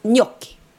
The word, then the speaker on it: gnocchi: Och det uttalas inte så. Det uttalas gnocchi. – Gnocchi. – Jo, men jag sa ju det, gnocchi. Och vet du vad det betyder? Ja, gnocchi. – Alltså, NJ, alltså när man uttalar NJ.